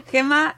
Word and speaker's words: Gemma: Y hoy hablamos con Gemma, no sé si Gemma, ¿cómo quieres que lo pronuncie? – Gemma. Está bien, soy catalana, pero me va bien que me digas Gemma. – Gemma, espérate que diga bien el apellido.